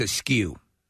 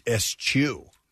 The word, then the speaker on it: eschew: I hear people mispronounce it all the time as askew. – Askew? – Yep. – This says it's eschew by the pronunciation. – Yep. – Look at that, eschew.